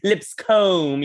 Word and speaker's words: Lipscomb: Yeah, as like I was bullied by it, so I'd always be like, oh no, pronounce it, because it used to have an E on the end of it, so I like pronounce it the old British way, Lipscomb, you know, I would insist upon it,